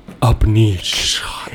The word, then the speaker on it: UPNEEQ: It's UPNEEQ. Upneek. And all day long after I got it, I kept going, Upneek.